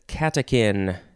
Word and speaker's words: catechin: The first word is catechin, C-A-T-E-C-H-I-N. It's like the chin of a cat, the catechin. No, it's spelled with a chin, pronounced like a kin.